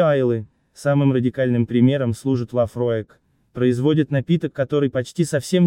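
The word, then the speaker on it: Laphroaig: А третье действительно чудное, Лафроек. – Лафроек.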